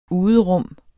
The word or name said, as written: uderum